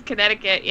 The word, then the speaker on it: Connecticut: Fun fact, Connecticut has a silent C in it. Didn't know that. – It does, Connecticut, yeah.